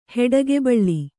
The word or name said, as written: ಹೊಟ್ಟೆಗೊಬ್ಬಳಿ